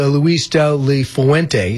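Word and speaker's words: Luis de la Fuente: He said as soon as he was done playing, he went to Spain to a coaching academy, and his coach was Luis Del Le Fuente. Is that how you say it? – Yep.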